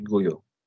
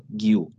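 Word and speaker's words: Guiu: رضا راجع به گویو گفتی که باید بگم دو تا تلفظ داره اسمش یکی هم داخل زبان اسپانیایی یعنی اسپانیا چیز دیگه بهش میگن مارک گویو تلفظ اسپانیش اینه تو زبان کاتالان بهش میگن مارک گیو یعنی اون یوی که داره حالا سایلنت خونده نمیشه به صورت کلی یعنی تلفظش حالا کسی بخواد کاتالان بگه میشه گیو اسپانیا دیگه شما میشه گویو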